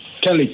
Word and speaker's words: Kelly: Say that again, Charlie. – Kelly. – Telly. How do you spell that? T-E-L-E. – K-E-L-L-Y. – Kelly. Oh, sorry about that. I thought I heard Telly.